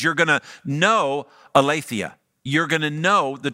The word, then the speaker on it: aletheia: And the Greek word for truth there is aletheia. Aletheia probably is my better way to pronounce it.